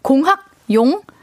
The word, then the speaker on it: 공학용: 김태양님께서 공항 계산기요 이런데 노노노 아 배우가 돼가지고 발음이 공항 계산기 말고 공학 공학용 뭐 공학용 계산기요 네.